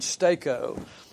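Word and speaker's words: steko (στήκω): It's S-T-E-K-O. And it sounds like even, as MacArthur said, it sounds like you're driving a stake in the ground, okay? You're making something firm. At least that's the way you pronounce the word, steko.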